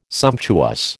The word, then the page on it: sumptuous: American English